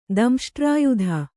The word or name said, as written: ದಂಷ್ಟ್ರಾಯುಧ